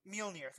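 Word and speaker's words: Mjölnir: I can never pronounce this right, but his hammer, Mjolnir, I think, or I'm about to get kind of angry. Mjolnir, thank you.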